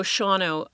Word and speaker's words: Shawano: So Shawano, I don't know exactly how we came to that pronunciation in English, but it's Sawano in, mm-hmm. in Menominee.